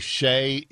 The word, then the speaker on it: Shea: Pronouncing S-H-E-A, Shay, is an act of violence in that version of America.